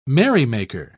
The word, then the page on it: merrymaker: British English